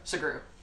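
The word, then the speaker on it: Sugrue: Segru. – Okay. Is there only one R? – Yeah, so it's S-U-G-R-U-E.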